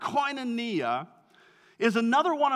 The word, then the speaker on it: koinonia: It's pronounced koinonia.